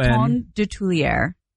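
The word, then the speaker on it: Coton de Tuléar: Is it spelled like cotton? How do you spell it? One T. C-O-T-O-N. – Coton? – C-O-T-O-N. – Coton de Tulier. – How the hell do you spell that? – Of Tulier. D-E-T-U-L-E-A-R.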